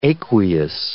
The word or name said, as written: aqueous